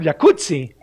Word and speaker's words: jacuzzi: Entonces deberíamos pronunciar el jacuzzi. – Exacto. – Como pizza. Como Rampazzo, como Claudia Rampazzo.